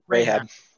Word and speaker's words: Rahab: Rahab.